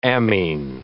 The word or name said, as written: ammine